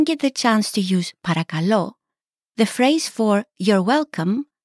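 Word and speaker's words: Parakalo (παρακαλώ): Parakalo.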